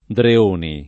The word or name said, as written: Dreoni